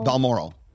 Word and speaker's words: Balmoral: Balmoral.